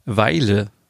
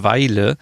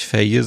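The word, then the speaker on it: Vejle: Weile dazu. Also die Stadt heißt Weile und nicht Feje, so wie ich immer dachte, so das ist immer, wenn Deutsche irgendwie andere Städte aussprechen, so äh, wie Oslo, aber eigentlich heißt es Uschlo.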